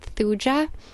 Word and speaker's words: Thuja: and it is the homeopathic remedy called Thuja, and that's spelled T-H-U-J-A. Some people pronounce it Thuja, but that is the homeopathic remedy for warts.